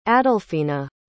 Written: adolfina

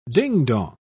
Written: dingdong

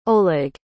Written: Oleg

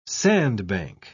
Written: sandbank

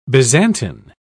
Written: byzantine

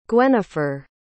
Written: gwenifer

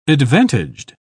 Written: advantaged

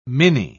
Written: mini

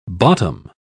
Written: bottom

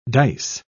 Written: dice

